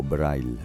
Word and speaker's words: Breil: Breil, o Braille, pronunciado a la alemana, considerado uno de los primeros profesionales de bandas sonoras.